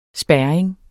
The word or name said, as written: spærring